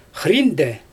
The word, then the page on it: xriinde: Xyzyl